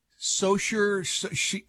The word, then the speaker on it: Saoirse: Saoirse, Saoirse Ronan. – Saoirse Ronan. – Saoirse, I think it is. Saoirse Ronan. Yeah, anyway. – Saoirse Ronan. – It looks like Saoirse, but anyway, she's playing Linda McCartney, the late Linda McCartney.